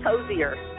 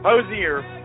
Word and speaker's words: Hozier: Dozier, I think. I think it's Hozier. – Hozier.